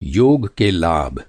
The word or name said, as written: योग